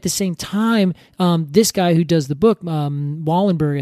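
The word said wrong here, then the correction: Wohlleben: He's a German man, and I keep on saying his name wrong. – Peter Wallenben. – Wallenben, that's it.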